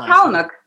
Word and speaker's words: Palahniuk: Chuck Palahniuk, however you say it. Chuck Palahniuk.